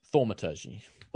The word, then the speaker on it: thaumaturgy: How do you say that word? – Thaumaturgy. – Thaumaturgy. – I always pronounce it Thaumaturgy.